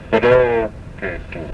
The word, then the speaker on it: Mdoketu: Summer, Baroketu. There's that B sound, and it's spelled in the dictionaries, M-D-O-K-E-T-U. And it's pronounced Baroketu.